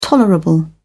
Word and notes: tolerable: British English

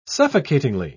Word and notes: suffocatingly: British English

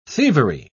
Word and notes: thievery: British English

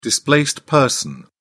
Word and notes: displaced person: British English

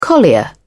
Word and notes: collier: British English

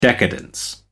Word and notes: decadence: American English

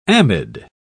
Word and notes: amide: British English

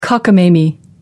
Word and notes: cockamamie: American English

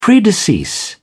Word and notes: predecease: British English

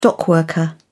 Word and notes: dockworker: British English

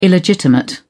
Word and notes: illegitimate: British English

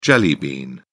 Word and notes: jelly bean: British English

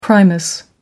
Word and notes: primus stove: American English

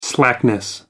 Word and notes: slackness: American English

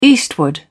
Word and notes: eastward: British English

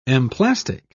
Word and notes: emplastic: British English

